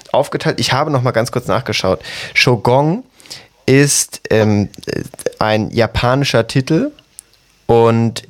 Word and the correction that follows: Shogun: Ganz kurz, das heißt Shogun.